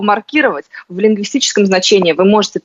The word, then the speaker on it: маркировать: У лингвистов есть такой термин, тот самый, который сейчас Есения как раз употребила, и там как раз ударение ставится на букву «и». А вот маркировать, предположим, продукты в холодильнике, вот там уже будет другое ударение. Просто разные смыслы. У нас такое бывает, в зависимости от смысла меняется ударение.